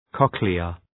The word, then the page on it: cochlea: 'kɒklıə